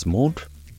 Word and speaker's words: menthe: Spearmint in French is pronounced menthe there and spelt menthe, M-E-N-T-H-E, and verte, V-E-R-T-E.